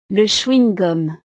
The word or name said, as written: le chewing-gum